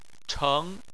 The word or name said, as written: cheng2